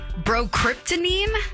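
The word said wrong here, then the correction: bromocriptine: Bromocryptine. That's it. Bromocryptine.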